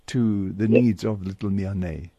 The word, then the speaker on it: Miane: Miane. – Miane, yes.